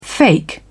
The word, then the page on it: fake: British English